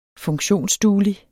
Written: funktionsduelig

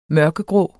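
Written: mørkegrå

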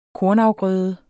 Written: kornafgrøde

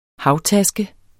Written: HAVTASKEN

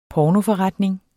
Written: pornoforretning

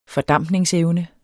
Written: fordampningsevne

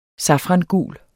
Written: safrangul

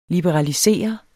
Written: LIBERALISEREDE

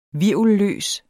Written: hvirvelløs

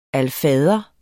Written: alfader

